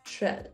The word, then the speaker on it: Tirrell: We have a very cool guest with us, Francine Tirrell.